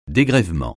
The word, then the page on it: dégrèvement: French